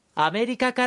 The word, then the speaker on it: アメリカ: アメリカから来ました。 – Now let's go over it. – どちらからですか? – Where are you from? – It's an expression asking where the person you're talking to is from. どちらis an interrogative which means where. – アメリカから来ました。 – I'm from America. – America is pronounced as アメリカ。